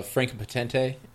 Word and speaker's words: Franka Potente: You have uh, uh, Franka Potente as a wonderful love interest, if you will. Uh, did I get her name right? Yep.